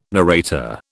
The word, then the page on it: narrator: British English